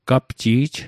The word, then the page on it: gapji'j: Mi'gmaq